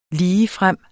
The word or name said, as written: ligefrem